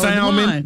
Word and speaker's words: salmon: That's salmon. That's salmon. – Salmon has that, well, that L is supposed to be silent, ain't it? – Kind of, sort of.